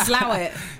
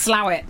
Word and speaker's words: Slaithwaite: Slough. – Yeah. – It's spelled Slaithwaite. But it's pronounced Slough.